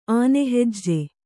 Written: ಆನೆಹೆಜ್ಜೆ

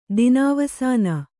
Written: ದಿನಾವಸಾನ

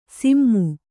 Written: ಸಿಮ್ಮು

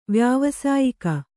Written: ವ್ಯಾವಸಾಯಿಕ

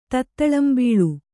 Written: ತತ್ತಳಂಬೀಳು

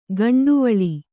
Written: ಗುಂಟವಲೆ

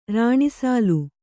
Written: ರಾಣಿಸಾಲು

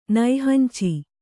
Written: ನೈಹಂಚಿ

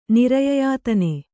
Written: ನಿರಯಯಾತನೆ

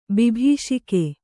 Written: ಬಿಭೀಷಿಕೆ

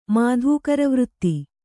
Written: ಮಾಧೂಕರವೃತ್ತಿ

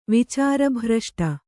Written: ವಿಚಾರಭ್ರಷ್ಟ

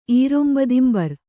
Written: ಈರೊಂಬದಿಂಬರ್

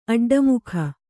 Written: ಅಡ್ಡಮುಖ

